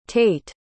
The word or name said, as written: tait